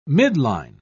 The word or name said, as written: midline